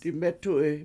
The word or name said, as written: dimEtu7i